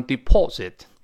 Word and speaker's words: deposit: d e p o s i t d e p o s i t 我们读一下 deposit，deposit，但是英国人读成 deposit，美国人把 o 变成 r 叫 deposit，读快之后那个车听不到叫 deposit，deposit 就好了。